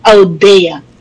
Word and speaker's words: Audea: Tama naman po ang pronounce ninyo ng aking last name, Audea.